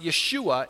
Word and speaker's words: Yeshua: His name in Hebrew is pronounced Yeshua, and it means God saves.